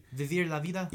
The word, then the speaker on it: Vivir la Vida: Vivin la Vida? – No. Vivir. The fuck, man? I'm pretty sure I'm pronouncing it correctly. – Vivir la Vida? – Yes.